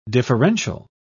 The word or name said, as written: differential